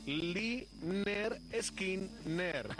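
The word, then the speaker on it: Lynyrd Skynyrd: Porque en este álbum que escuchamos, que se titula Pronunciate. Leaner Skinner. Así separadas las sílabas para que quede claro claro, porque no era una expresión que significara nada para el gran público.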